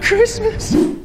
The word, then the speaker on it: Christmas: Like Christmas. Christmas. Christmas. Cres como el nombre de persona, Chris, Chris, y luego mes. Christmas. Christmas. La T no se pronuncia.